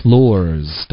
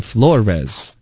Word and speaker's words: floors: floors 的发音是一个音节，ors 不要发成 flores。